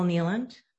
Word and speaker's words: Kneeland: My name is Nicole Neeland, K-N-E-E-L-A-N-D.